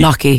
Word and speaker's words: gnocchi: Gnocchi. – Gnocchi. – It's a silent G. It's like knee.